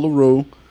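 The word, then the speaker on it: Larrieux: So we got Amel LaRue. You got BJ. – I did not know that's how you pronounce her name.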